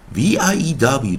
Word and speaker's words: view: 那在 v i e w 的时候啊，我们 w 发音为也是 u u u，也就是我们知道，当结尾是 u 或 w 的时候，它有两种发音 u 以及 u，所以在 v i e w 我们发音为 view。